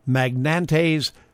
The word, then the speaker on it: Magnante: It's Charles Magnante's Polka Party, I think. M-A-G-N-A-N-T-E, apostrophe S. Charles Magnante's Polka Party is how I've decided to pronounce it.